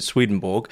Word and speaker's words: Swedenborg: Well, the English would pronounce it Swedenborg, but as my Swedish friend has told me, it's more like, and I'll mispronounce this because Swedish pronunciation is beyond me, but something along Emanuel Swedenberg, something along those lines.